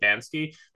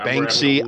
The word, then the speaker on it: Banksy: And he became like this, yeah, they're that annoying, is that how you say it, Bansky? Anyway, I'm trying to articulate something here. – Banksy,